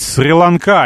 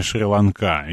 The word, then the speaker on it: Шри-Ланка: Значит, Игорь меня поправляет, что правильно говорить «Сри-Ланка», а не «Шри-Ланка».